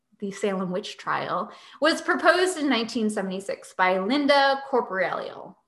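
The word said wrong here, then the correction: Caporael: Now that's too many letters. Caporeal.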